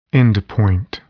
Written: endpoint

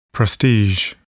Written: prestige

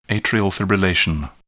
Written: atrial fibrillation

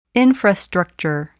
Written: infrastructure

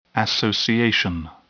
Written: association

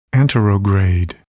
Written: anterograde

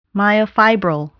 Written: myofibril

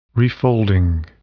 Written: refolding

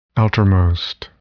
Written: outermost

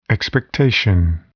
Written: expectation

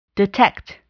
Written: detect

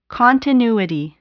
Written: continuity

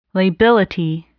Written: lability

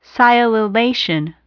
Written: sialylation

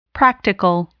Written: practical

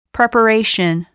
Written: preparation

